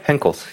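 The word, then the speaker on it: Henckels: You know Henkels? – Yeah, Henkels.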